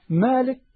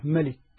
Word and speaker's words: مالك: مالك وفي قراءة متواترة ملك، فالله عز وجل هو المالك سبحانه وتعالى، مالك إيه؟